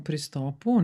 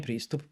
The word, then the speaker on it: pristop: Ove, ovaj, pa ja sam u pristopu, nije pristup, pristop je bio uvek, ili pit stop, što smo se mi zazvali. – Pristop. Pristop. Ja sam sad dobro izgovorio.